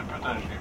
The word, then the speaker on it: Peutinger: il y a des gens qui disent qu'il tape de Peutinger, quoi, je le prononce en allemand, oui.